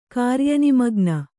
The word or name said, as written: ಕಾರ್ಯನಿಮಗ್ನ